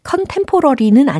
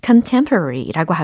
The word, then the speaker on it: contemporary: 라고, 예, 하시는 분들도 계시는것 같은데, 어, 동시대의 현대의 라는 의미로 C-O-N-T-E-M-P-O-R-A-R-Y 라는 철자입니다. 과연 발음을 어떻게 하면 좋을까요? 컨템포러리는 아니겠죠. 예. 발음을요. 컨템 n 러리 이렇게 연습하시면 좋습니다.